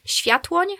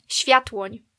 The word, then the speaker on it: Światłoń: Es würde eigentlich Światłoń heißen. – Nochmal? – Światłoń. – Okay. – Ja, ist ein bisschen schwierig zu aussprechen. Aber in der deutschen Schreibweise ist einfach Sviathlon.